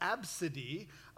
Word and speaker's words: ABCDE: of ABCDE. You pronunciate that Absidy, but her name was ABCDE.